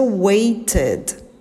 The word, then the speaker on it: waited: Wait no passado vai ser a pronúncia waited. Por quê? Porque wait termina com som de T.